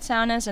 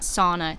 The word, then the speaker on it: sauna: Do you know the Finnish people invented saunas? And that's how you actually pronounce it. It's not sauna, it's sauna.